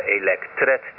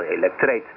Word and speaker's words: elektreet: En ik hoor, waar ga ik wat nadruk leggen op de uitspraak, omdat je heel vaak hoort dat in Nederland we daar elektret tegen zeggen. En dat is gewoon, in het Nederlands is dat elektreet.